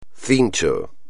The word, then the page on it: cincho: espagnol